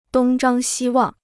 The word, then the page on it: 东张西望: dōng zhāng xī wàng